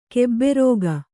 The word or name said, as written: ಕೆಬ್ಬೆರೋಗ